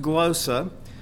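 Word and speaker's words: glossa (γλῶσσα): I always like to pronounce that glossa because it just sounded better. But it is pronounced glossa, which means the language used by a particular people in distinction from that of other nations.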